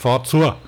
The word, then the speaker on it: Fazua: Weiß nicht, ob ich das korrekt ausgesprochen habe. – Fazua, klar. – Fazua. Naja, Fazua. Im Fränkischen würde man zu sagen. Aber der Bayer sagt Fazua.